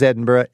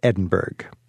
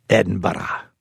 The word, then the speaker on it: Edinburgh: It's spelled like Pittsburgh. – Well, it's definitely not Edinburgh. Locals say something like Edinburgh. But I usually settle on simply Edinburgh.